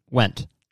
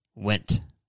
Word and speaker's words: Wendt: Wendt. Ian Wendt to AlphaCon to do a speech. Ian, W-E-N-D-T. Went.